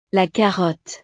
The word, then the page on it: la carotte: frances